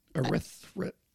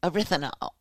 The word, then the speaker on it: erythritol: Say it like French. – I can't. – Erythritol. – Erythritol.